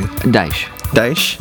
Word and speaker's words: Dyche: Sean Dykes, their coach, they got... Did I say that right? – Not quite, but that's all right. – Well, we'll correct me. – Dyche? – Yeah.